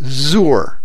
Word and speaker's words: zur: That's the Hebrew word zur. Zur, the Hebrew word rhymes with sewer, which is an appropriate analogy.